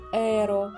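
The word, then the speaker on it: héros: Во французском языке существительное произносится как «эро», и непонятно, откуда в русском слове появилось «и» краткое на конце.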